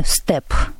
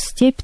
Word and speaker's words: степь: Ну, естественно, опять-таки, это будет по произношению степ. То есть твердо произносится у нас степь, там степ.